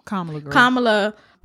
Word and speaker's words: Kamala: Kamala palatable because they are generally like the better blacks.